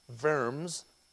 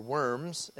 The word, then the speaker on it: Worms: And he convinced Charles to summon Luther before the Council of German Rulers, which was to be held in April in the city of Worms, or Worms, as it's spelled in English.